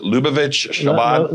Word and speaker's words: Lubavitch Chabad: I can't pronounce it, Lubavitch Chabad. – Lubavitch Chabad. – I wasn't even close.